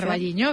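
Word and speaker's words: O Carballiño: Ocarvalliño, no lo sé pronunciar. – Ocarvalliño, bien, exacto.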